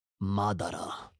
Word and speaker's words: Madara: Can you pronounce his name properly, please? No, it's Madara. – Madara. – That's Madara, better.